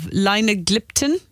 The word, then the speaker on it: linagliptin: She's on 5 milligrams of linagliptin, if I'm saying it right.